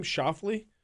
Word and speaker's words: Schauffele: How do you say his last name? Schauffele? – Yeah. Yep.